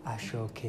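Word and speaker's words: aso oke: A-S-O space O-K-E, ashoke. In the Yoruba culture, S is pronounced in this case as in S-H. So there's an accent on the S. So it's ashoke. So A-S-O instead of aso is ashoke.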